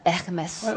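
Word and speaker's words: Hermès: 咁 Hermes 咧？ – Hermes 都有啲人問過我，其實因為法文音 H 係唔發音嘅，所以係 Hermes。咁個 R 音就要震呢個位啦，所以就係 Hermes。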